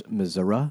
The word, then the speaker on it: Missouri: I believe that's pronounced Missouri.